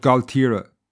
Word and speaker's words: Gaultier: And if you travel from Dunmore East to Waterford City, you pass through an area called Galtiera. Now, this is almost French sounding, and when you see it written down, it's spelled G-A-U-L-T-I-E-R, you'll be forgiven for thinking it was perhaps French in origin.